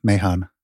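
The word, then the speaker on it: Mahon: Is it Mahan or Mahan? – It's M-A-H-O-N, Mahan.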